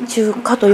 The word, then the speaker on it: 中華: はい、ね、中華ってこう中心の中に平和の和と書いて中華と読むんですね、はいはい、私最初なんて読むんだろうって思いながらこっちに来て初めて知りました。